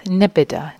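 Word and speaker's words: nibbidā: So I'll start with nibbida, the right pronunciation in Pali.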